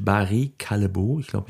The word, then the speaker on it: Barry Callebaut: Qualcomm legt seine Quartalsergebnisse vor, ebenso Kraft Heinz, dann die beiden Schokoladenriesen Mondelez und Barry Callebaut.